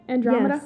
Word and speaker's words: Andromeda: How do you say that? Is that how you say it? Andromeda? – Yes.